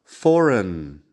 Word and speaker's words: foreign: Dos ejemplos con la G que no suena, que también es bastante común. Foreign de extranjero.